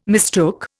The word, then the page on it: mistook: Indian